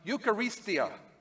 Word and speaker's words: Eucharistia: It is pronounced Eucharistia, which is where we get our word Eucharist from.